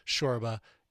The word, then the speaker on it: Csorba: Thomas Shorba and his cover of Plastic Jesus. Go check out the album. C-S-O-R-B-A is how you pronounce his last name.